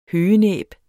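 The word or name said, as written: høgenæb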